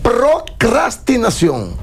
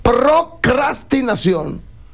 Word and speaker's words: procrastinación: Procrastinación. Cuando usted procrastina algo, usted la pospone. – Ese cras es importante. Muchos le quitan la R y dicen cas y lo hacen mal.